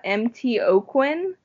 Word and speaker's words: MTaucoin: You can find me on Twitter at MTOquin. I'm going to have to spell that for you because my last name is like French Cajun and no one ever gets it. It's MTAUCOIN.